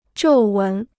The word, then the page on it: 皱纹: zhòu wén